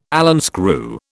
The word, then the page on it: Allen screw: British English